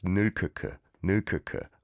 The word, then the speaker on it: 늙었구나: Give it a try on your phones and you can see it's spelt out with 늙. So it's got the 리을 기억 받침 and the English romanization says 늙었구나, but it's just pronounced 늙었구나.